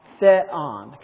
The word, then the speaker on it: Theon: Now, taking a look at that word again, the epsilon is a short e, like pet. Okay, so it's Theon, kind of hard to say,